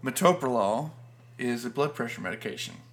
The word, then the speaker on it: metoprolol: A lot of people that don't know how to pronounce it call it metoprolol.